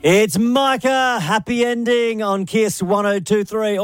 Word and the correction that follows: Mika: I think it's pronounced Mika.